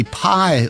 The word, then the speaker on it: Pye: By the way, The Searchers recorded for the Pi label, and that's spelled P-Y-E, and that rhymes, well, it rhymes with cry, as in, I'll cry tomorrow.